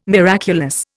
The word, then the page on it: miraculous: Indian